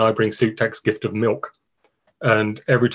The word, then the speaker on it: MIWK: It's pronounced M-I-W-K. – Yeah. – I've been saying MOOC in my head for years, but I have now finally going to find out, is MOOC the correct pronunciation of MOOC? – I think any pronunciation is correct because it's a made-up word, but it's milk.